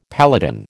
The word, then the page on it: paladin: American English